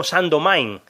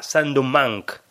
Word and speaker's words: Saint-Domingue: porque venía a decir algo así como Saint-Domingue varias veces, y eso principalmente es porque la documentación que usé y algún podcast que escuché de la BBC hablando del tema, pues utilizaba esa pronunciación, Saint-Domingue. Cuando habría que ponerla en francés un poco más y decir algo parecido a Saint-Domingue.